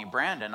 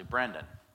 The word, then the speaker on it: Brendan: Kind of like if you call me Brandon, I'll still answer to that, even though it's actually Brendan.